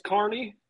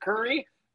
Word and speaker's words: Kearney: You're halfway sir, it is Kearney. If you pronounce it Kearney, they will actually hang you up by the trees and beat the shit out of you with metal rods.